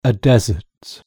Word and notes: desert: British English

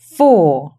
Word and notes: four: British English

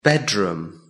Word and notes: bedroom: British English

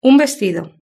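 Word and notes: un vestido: Spanish (Spain)

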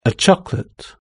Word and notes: a chocolate: British English

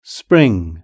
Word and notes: spring: British English

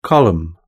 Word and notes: column: British English

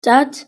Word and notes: dad: British English

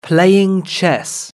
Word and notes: playing chess: British English

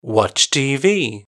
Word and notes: watch tv: British English

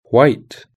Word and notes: white: British English

